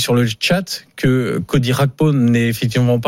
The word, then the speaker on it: Gakpo: On dit effectivement Cody Gakpo, même si son nom n'est pas d'origine. – Bon, chacun dit comme il veut. On sait moi les prononciations. – Moi, je vais dire Gakpo parce que c'est important.